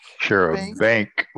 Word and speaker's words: charabanc: The next word is sharebang. And I spell it C-H-A-R-A-B-A-N-C. Share a bank with a C on the end.